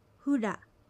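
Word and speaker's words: フラ: ふら、ふらは匂いという意味です。アクセントはふにあります。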